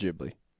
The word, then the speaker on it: Ghibli: It's pronounced Ghibli. – Ghibli? – Yeah, like giblets.